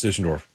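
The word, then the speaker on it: Düsseldorf: It's Dusseldorf Jr. You think that's pronounced Dusseldorf? – It is pronounced Dusseldorf. I was in Germany, 2 months ago? That's exactly how they would have said it.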